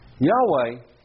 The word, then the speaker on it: Yahweh: Yahweh, which is probably the most accurate way to pronounce the four letters that stand for God's name in the Hebrew text, Yod, He, Vav, He.